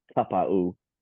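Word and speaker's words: Taupau: Oh, Marty Kapow Tapow apparently is a Tapa'u. Give me a spell.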